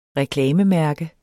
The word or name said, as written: reklamemærke